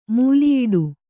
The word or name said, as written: ಮೂಲಿಯಿಡು